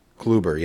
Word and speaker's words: Kluber: And getting Corey Kluber, right? – Kluber, yes.